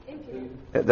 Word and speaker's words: impudent: For they are impotent children. That's not, how do you say that word? Impudent? – Impudent. – I said it right. I said it didn't sound right.